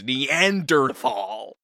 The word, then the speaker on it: Neanderthal: Because you're a Neanderthal. – Why are you pronouncing it like that? – Like what? – Neanderthal. – Is that not how it's pronounced? – No, it's Neanderthal.